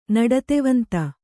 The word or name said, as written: ನಡತೆವಂತ